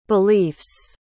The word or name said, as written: beliefs